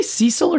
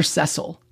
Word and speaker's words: Cecil: And it starts with diamonds and Cecil Rhodes. Do you say Cecil or Cecil? – Americans say Cecil, but the rest of the world says Cecil.